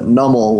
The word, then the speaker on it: Numel: Wait, the running shoes are on the other foot, because normally I'm the one that says it wrong, but it seems that in this instance that Numel, which was your guess, was correct.